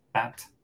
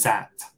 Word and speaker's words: that: Y no a confundir con la S, ¿no? – que algunas personas eh, podrían decir that, pero no es that, ¿no? sería that.